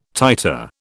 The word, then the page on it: titer: British English